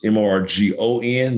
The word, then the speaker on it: Morgon: That's Morgon, not Morgan. Okay. My name is spelled with two O's, not an A.